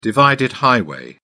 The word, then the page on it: divided highway: British English